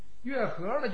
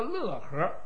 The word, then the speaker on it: 乐和: 月和呢就是音乐那个乐，有人说他叫乐和，乐和也好，月和也好，这两个读音都是一个姓氏。